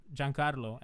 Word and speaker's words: Giancarlo: No, honestly, like, and I was actually talking about this with a friend the other day that, like, my name's Giancarlo, and in Italian, you pronounce it Gianni, like, for short.